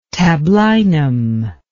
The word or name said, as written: tablinum